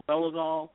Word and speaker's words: Dolezal: The Caitlyn Jenner and the Rachel, and how do you pronounce her last name? – Dolezal.